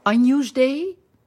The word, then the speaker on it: Agnus Dei: Dan gaan we naar de laatste ronde met het Agnus Dei.